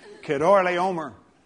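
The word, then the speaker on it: Chedorlaomer: I mean, that's not the way they pronounce it, but that's the way we pronounce it because you can't remember how to say it the other way. Kedorlay Omer.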